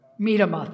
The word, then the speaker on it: Medema: Medema, thank you. I say it wrong every time.